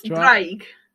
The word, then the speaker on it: Draig: Drag? – Close. Draig.